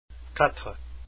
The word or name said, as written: quatre